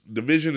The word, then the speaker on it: DVSN: So the project I want to talk about this week is from Division, and Division is spelled DVSN, and it's called Working on My Karma.